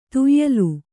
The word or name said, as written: ತೇಯೆಲೆ